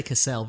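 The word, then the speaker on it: Cassel: How do you say that? Castell? – I say Cassell, Vincent Cassell.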